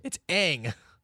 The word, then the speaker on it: Aang: It's Aang.